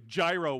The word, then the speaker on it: gyro: Now, please don't lecture me on how to pronounce the word gyro. I don't want to have that conversation. I've studied Greek. It makes no sense. So if you're saying, oh, it's pronounced euro, then spell it that way.